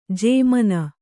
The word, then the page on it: ಜುಮ್ಮೆನ್: jummen